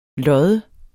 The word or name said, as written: loddes